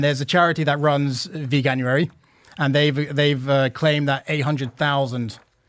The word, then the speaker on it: Veganuary: So it's effectively about veganism and veganuary, if I'm saying that correctly.